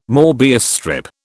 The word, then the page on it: MÔbius strip: British English